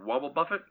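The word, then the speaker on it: Wobbuffet: Wobble Puppet? – Yeah. – Wobble Puppet? Wobble Buffet? – There it is.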